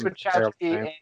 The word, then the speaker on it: Wachowski: it's wachowski and I'm just, I'll bone up on my pronouns, see what they re- see how they identify, and then go.